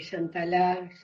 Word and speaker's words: Chantalá: diferenciación de que Chantalá se pronuncia con acento de la última A